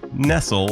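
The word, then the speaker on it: Nestle: So listen in as Dr. Nessel, yes, her name is pronounced Nessel like the verb and not like the food company, and I talk about a life in food studies and food politics.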